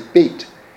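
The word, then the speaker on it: beit (בית): I'm saying Bez because that's the Ashkenazi pronunciation, but really you would say beit.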